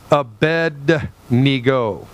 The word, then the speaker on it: Abednego: You have the king who says to Shadrach, Meshach, and Abednego. It's Abednego, is how you pronounce it.